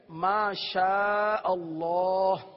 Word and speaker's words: ما شاء اللہ: تلفظ کی درستی کے حوالے سے ایک کلمہ ہے, ایک ذکر ہے, ما شاء اللہ. آج اس کی تھوڑی سی مشق کر لیں گے.